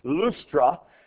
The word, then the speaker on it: Lystra: Looks like Lystra. It's actually pronounced Lustra, but that's a city there that they end up in.